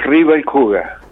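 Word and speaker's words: Riebel Kuchen: La pronunciación es una pronunciación de los alemanes del Volga y es Rihuel Cuyén.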